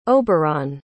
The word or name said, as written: Oberon